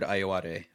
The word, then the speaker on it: Ayoade: This is actor Richard Ayoade, whose name I think I'm pronouncing right. – Yeah, that's correct, I believe.